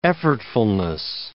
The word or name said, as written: effortfulness